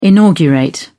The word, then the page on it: inaugurate: British English